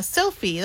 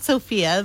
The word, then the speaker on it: Sophie: Yeah, Sophie. That's how you say it. It's not Sophia. There's no A at the end. It's Sophie.